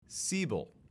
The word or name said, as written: Siebel